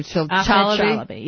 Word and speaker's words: Chalabi: Chalabi, yeah.